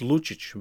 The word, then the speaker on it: Lucic: Is it Lukic? – It's Lucic, but you're close enough.